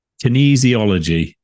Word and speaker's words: kinesiology: I'm going to sound like a bit of an idiot now, and not for the first time probably, but kinesiology, is that how you pronounce it? – Yeah.